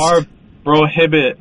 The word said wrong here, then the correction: Amor Prohibido: How would you say it? – Amor Prohibido. – Yes.